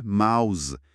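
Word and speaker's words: mouths: ببینید، توی حالت مفرد ماوث صدای تی بی صداست، مثل صدای سه خودمون. – آها. – اما وقتی جمع بسته میشه، یعنی ماوثس، اون تی ایچ یهو صدادار میشه، شبیه ز ما. یعنی تلفظ از ماوث میشه ماوز.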